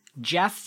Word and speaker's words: Gef: Jeff the Talking Mongoose. – Oh, the Talking Mongoose. – He talks. – Okay. – Now, his name is spelled G-E-F, which kind of pisses me off already.